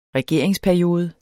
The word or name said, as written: regeringsperiode